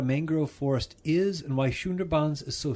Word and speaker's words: Sundarbans: Am I pronouncing that correctly or not? – Yes, we call it Sundarbans. – Sundarbans.